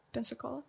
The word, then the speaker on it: Pensacola: But it's what rhymes with Coca-Cola? – A city. Rhymes with Coca-Cola. Pensacola.